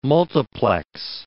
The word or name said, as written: multiplex